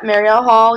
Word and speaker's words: Marielle Hall: How do you say her name? Marielle Hall? – Yep, Marielle Hall and Kate Grace just joined this fall.